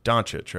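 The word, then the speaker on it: Dončić: Doncic, right? I'm saying that correctly? – Yeah.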